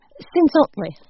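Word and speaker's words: Cenzontle: This is Cinzontle? Am I saying that right? – Cinzontle.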